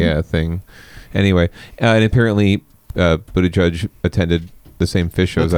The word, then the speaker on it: Buttigieg: But it's Buttigieg, if you listen to his Twitter bio. Buttigieg is how you pronounce it.